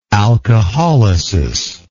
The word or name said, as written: alcoholytic